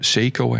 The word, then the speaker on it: Seiko: Seiko, I have to say. Otherwise, we get a lot of comments about Seiko. This is how we pronounce it in the Netherlands.